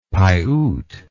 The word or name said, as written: piute